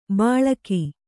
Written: ಬಳಗ

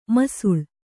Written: ಮಾಸಾಳು

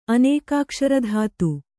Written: ಅನೇಕಾಕ್ಷರಧಾತು

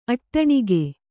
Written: ಅಟ್ಟಣಿಗೆ

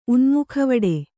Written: ಉನ್ಮುಖವಡೆ

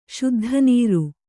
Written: ಶುದ್ಧನೀರು

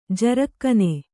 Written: ಜರಕ್ಕನೆ

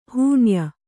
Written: ಹೂಣ್ಯ

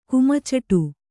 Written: ಕುಮ್ಚೆಟ್

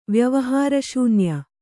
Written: ವ್ಯವಹಾರಶೂನ್ಯ

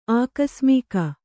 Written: ಆಕಸ್ಮಿಕ

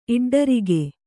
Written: ಇಡ್ಡರಿಗೆ

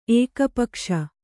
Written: ಏಕಪಕ್ಷ

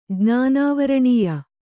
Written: ಜ್ಞಾನಾವರಣೀಯ

